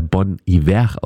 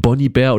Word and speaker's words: Bon Iver: dass er Bon Iver ausgesprochen wird und nicht Bon Iver oder Bon Iver, wie viele damals ja immer noch annahmen.